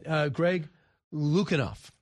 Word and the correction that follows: Lukianoff: L- Lukianoff. – Lukianoff. Thank you very much. I missed somewhat of a syllable there.